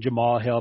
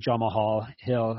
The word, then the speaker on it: Jamahal Hill: you may want to call him Jamal Hill, but I call him Jamahal Hill because that's how it's spelled.